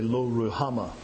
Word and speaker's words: Lo Ruhamah: And I'm using the Hebrew here, and that's why I have that guttural sound, because that's how the H is to be pronounced in the middle. You would say Lo Ruhamah, but it's Lo Ruhamah.